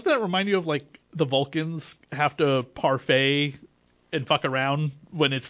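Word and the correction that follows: Pon farr: Am I saying it correctly? – No, it's Ponfar. – Okay. – Ponfar, isn't that? I think that's the one. – You got it right. Ponfar.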